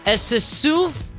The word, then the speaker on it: Acesulfame: In addition, um, I'm gonna try to pronounce this correctly, okay? Esisuf S okay, this type of potassium. A C E S U L F A M E.